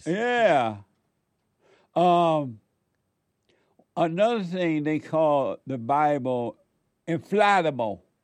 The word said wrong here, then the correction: infallible: Would you please pronounce it correctly? – What? – You can say infallible or infallible. – F-A-L-L, right? – Right. – Infallible. – Or infallible. I don't know which one is more correct.